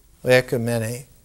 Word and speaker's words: ecumene: In Greek, it's spelled O-I-K-U-M-E-N-E, ecumene, from which we get ecumenical.